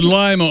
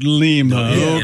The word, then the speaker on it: Lima: It's Lima, Ohio, not Lima.